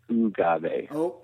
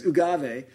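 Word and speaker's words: Oogave: And just for clarification, it's Ogave. – We made up a word that it doesn't adhere to any language pronunciation principles in its entirety. So sometimes people need a little assistance in pronouncing it correctly.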